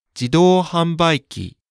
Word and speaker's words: 自動販売機: 自動販売機 Great, on to the grammar section.